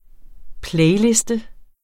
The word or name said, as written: playliste